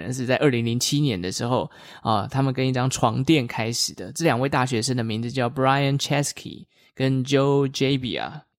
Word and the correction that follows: Gebbia: So Brian Chesky and Joe Gebbia? – Oh, yeah.